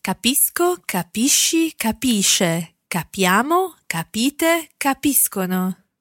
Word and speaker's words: capiscono: That's for the they form, the third person plural, which will be capiscono. – Capiscono. And of course, it's being followed by an O there. So capiscono. It's a hard C sound.